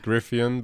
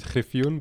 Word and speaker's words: Griffioen: Yeah, my last name is Griffioen, but in my language is Griffioen, but in your language, Griffin.